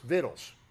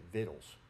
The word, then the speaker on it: victuals: It's spelled V-I-C-T-U-A-L-S, but it's pronounced vittles. And I grew up in West Tennessee hearing about vittles. And it's, I guess you're supposed to say it victuals. I don't know. But we said it vittles, Mary.